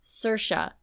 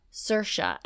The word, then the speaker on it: Saoirse: And finally, she was like, Saoirse, Saoirse.